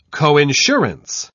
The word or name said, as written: coinsurance